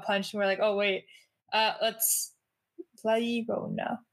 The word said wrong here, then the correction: La Llorona: La Llorona.